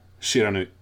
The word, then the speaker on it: Shiranui: Shiranui.